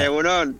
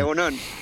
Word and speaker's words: Egun on: Egun On, ¿o cómo se pronuncia? – Egun On. – Egun On. – Sí, Egun On.